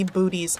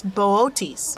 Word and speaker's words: Boötes: Mm-hmm, it's spelled Boötes, and I want it to be booties. I want it to be booties so bad. – It should be booties. – I love booties. – It should be. – But it's not. It's Boötes.